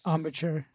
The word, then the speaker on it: embouchure: Yeah, how's that armature feeling all these years later? – Embouchure. – I always say it wrong.